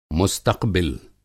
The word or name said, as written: मुस्तक़बिल